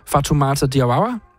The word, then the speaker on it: Fatoumata Diawara: Og du skal huske, hvordan man udtaler hendes skønne navn. Fatoumata Diawara. Hende, der har lavet Male Male og nogle andre kæmpestore hits. – Ja, flot udtale.